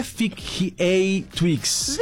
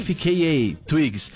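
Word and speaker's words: FKA twigs: É FKA Twix. Eu tive que pesquisar aqui. FKA. Faca Twix. FKA. FKA, Twigs.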